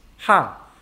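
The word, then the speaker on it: ha (ห้า): Yeah, in Thai language, the number 5 is pronounced ha.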